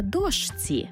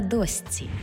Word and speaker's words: дошці: Наприклад, не «товаришці», а «товаристці». Не «мурашці», а «мурасці». І не «на дошці», а «на досці».